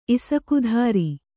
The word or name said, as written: ಇಸಕುಧಾರಿ